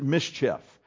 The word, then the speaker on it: MSCHF: Rapper Lil Nas X developed these shoes with a company called MSCHF, those five letters. They pronounce it Mischief.